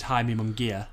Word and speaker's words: Jaime Munguia: Just Jaime Munguia.